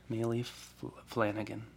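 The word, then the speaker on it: Maile Flanagan: How do you say her name? Maylee Flanagan. – Yeah.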